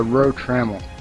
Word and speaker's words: Rotramel: Okay, just to make sure I'm pronouncing it right, it is Rotramel, correct? – Actually, it's Rotramel.